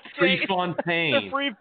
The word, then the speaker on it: Prefontaine: Profertine or Profer... – Prefontaine. – Prefontaine.